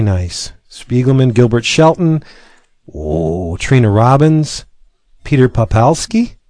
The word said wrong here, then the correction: Poplaski: Poplaski, I always mispronounce that.